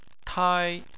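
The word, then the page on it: tai: tone 1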